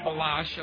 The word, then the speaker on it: Baloche: Also, the foreword in here is by Paul, is it Baloche? Is that how you say it? – Balash, okay.